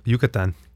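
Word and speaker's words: Yucatán: Yucatan.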